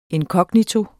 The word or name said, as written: inkognito